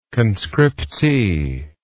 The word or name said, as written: conscriptee